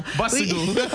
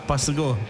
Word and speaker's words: pusiko: P-U-S-I-K-O. – Oh, we had to have a conversation about that. It sounds like a B, but spelled with a P? – Yeah, bussigoo. Bussigoo. – Oh, bussigoo. – Bussigoo. It's more like a B.